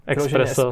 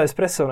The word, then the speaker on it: espresso: Nečte se to, nečte se to expre... Že jo, začali jsme tím bejzem a bájesem, můžeme skončit tím espresem a expresem. Takže je to S? Je to espresso, no jasně.